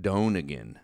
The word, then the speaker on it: Donegan: Donegan, not not like my friend Kyle Dunnigan. It's Brendan Donegan.